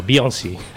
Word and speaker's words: Beyoncé: Beyoncé.